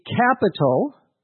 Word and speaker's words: capitol: C-A-P-I-T-A-L, is the city in which the government of a country meets and has its offices. Somewhat confusingly, in English, there's another word pronounced the same, capital, C-A-P-I-T-O-L.